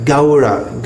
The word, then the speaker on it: Gaura: Gaura. Gaura. This sound. A-U is pronounced something like the sound in how, H-O-W, how, Gaura, Gaura.